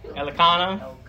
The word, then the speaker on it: Elkanah: So we see that Hannah was married to Elkan, right? That's how you pronounce his name, Elkan. Okay, Elkanah, okay.